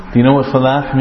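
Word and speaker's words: falah (فلاح): Which one was right? The second one. Because this is, where is this ha from? Which part? Middle of the throat. So we pronounce it falah.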